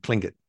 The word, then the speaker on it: Tlingit: Yeah, so it's spelled T-L-I-N-G-I-T, but it's actually, you actually say it, the actual letter, the way they say it, is a cross between a T and a K.